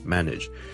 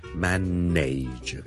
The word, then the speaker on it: manage: Ladies and gentlemen, boys and girls, 是鄭公司的 ，今天想跟大家講 manage 這個字的發音， manage M-A-N-A-G-E manage，由於這個字後面 N-A-G-E nudge 是串做 N-A-G-E，很多人就會將它讀成 manage，但事實上呢我這個字是讀 manage nudge nudge nudge，你當是好像 N-I-D-G-E 那樣。